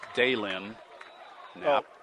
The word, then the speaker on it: Daylin Knapp: Taken at the 1 by Dylan Knapp. He takes it right up the middle and carries to the 20 to about the 22-yard line. – That's Daylin Knapp. Sorry, I didn't give you that pronunciation.